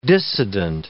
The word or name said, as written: dissident